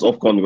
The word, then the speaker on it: AFCON: AFCON.